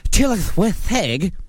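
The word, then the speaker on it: Tylwyth Teg: Various folklorist traditions refer to them euphemistically by names such as wee folk, good folk, people of peace, fair folk, which in Welsh is pronounced tylwytheg.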